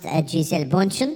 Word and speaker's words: Gisele Bündchen: In the United States, it is pronounced Gisele Bündchen.